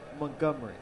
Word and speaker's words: Montgomery: Okay, so don't hit the T hard and say the first syllable like a U sound. Montgomery.